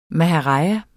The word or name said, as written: maharaja